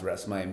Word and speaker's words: Reksmey: So my full name, Reksmey, or in Cambodian it's pronounced Reksmey, means a ray of light.